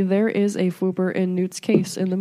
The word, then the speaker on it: Fwooper: There's a Fwooper in the Magical Menagerie at Diagon Alley, and you can silence it with the magic.